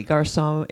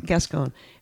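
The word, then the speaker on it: Gascón: But the latest thing is that, believe it or not, this, not Attorney General, what is he, Garcon in District Attorney. – DA in Los Angeles arrested. – Gascon.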